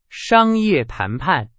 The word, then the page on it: 谈判: Chinese